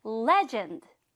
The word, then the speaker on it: legend: 악센트가 앞에. legend, legend. 네, 예를 들면 이러이러한 것들이 있어 라고 표현하면 좋겠죠?